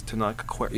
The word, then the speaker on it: Tenoch Huerta: Like, in interviews, the actor who played him, whose name I cannot pronounce, Tenoch Huerta, is that it? – Something like that. – Tenoch Huerta. – Yes, Tenoch Huerta.